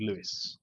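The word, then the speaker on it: Lewes: And there's a town outside of Brighton, which is spelt the same way, and it's called Lewis.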